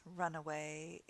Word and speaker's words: runaway: So in these examples, runaway is an adjective. Notice the pronunciation difference between runaway, one word, run away, two words, runway, one word, but a different meaning.